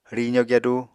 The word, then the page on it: rizo gyadu: Apatani